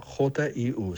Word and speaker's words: HU: La palabra Hue se escribe Hu, se pronuncia Jiu, suena así.